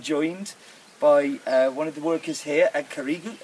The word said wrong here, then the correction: Karagiru: How do I pronounce the factory's name? – Alright, this factory is Karagiru. – Karagiru, you see, because I always get it wrong. I always pronounce names wrong, so Karagiru.